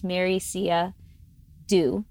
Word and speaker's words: Marysia Do: It's a Polish name, so it's not pronounced the way it's spelled. So it's Marysia Du, which is a Vietnamese name because my husband's Vietnamese.